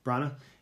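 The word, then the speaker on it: Branagh: Kenneth or Kevin Brennahan? I don't know. – Brana. – Yeah.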